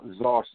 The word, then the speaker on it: Zoar: We went to like I told the listeners at the last show, a place called Czar Cemetery, and it's spelled Z-O-A-R Cemetery, which is across from the West Morris Mountain area.